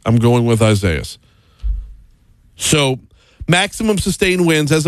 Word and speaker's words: Isaias: So where we're at right now with Hurricane Isaias, and yeah, no, I refuse to do the Isaiasis thing because it's spelled I-S-A-I-A-S. If you were to spell a name I-S-A-I-A-H, that would be Isaiah. You're swapping out the H for the S, but it's a different country of origin.